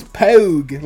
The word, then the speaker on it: Pogue: It's Pogue. Like Rogue, but Pogue.